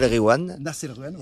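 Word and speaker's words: Nass El Ghiwane: Nas El Gwiam, voilà. Et quand il y a GH, tu dis re, tu fais comme si c'était un R.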